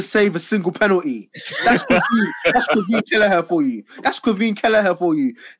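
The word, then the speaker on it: Caoimhín: How do you say his first name? – I don't know, and I don't care. – They got the Irish crew. It's Quiveen.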